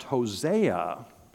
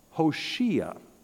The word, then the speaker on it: Hoshea: Maybe I should give you a clue and say that sometimes his name is not pronounced Hosea, but it's pronounced Hoshea.